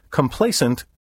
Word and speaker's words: complaisant: Complacent, with a Z sound in the final syllable, means inclined to please, gracious, obliging, courteous, affable, urbane. It has a positive connotation.